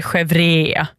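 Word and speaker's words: chèvre: Och jag ska nog inte försöka mig på franska ursättningen. Chevre, säger man.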